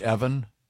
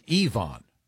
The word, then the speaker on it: Yvonne: Ryan is referring to a letter we got previously from Yvonne, whose name we pronounced Evan. Even though it's E-V-O-N, we just assumed it was an alternate spelling of Evelyn. He's Yvonne.